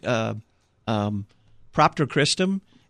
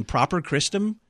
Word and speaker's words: Propter Christum: We did Propter Christum, and I did the entire spot saying Proper Christum. – But I think that's because I didn't put the T in your script.